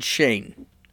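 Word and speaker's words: Schoen: Social media, there was another lawyer for Trump named David Shane, and it's spelled S-C-H-O-E-N. I heard someone pronounce his name, so I'm assuming that's what it is.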